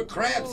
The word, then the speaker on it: crepes: I think they're called crepes. – No, they're crepes. He actually said it right.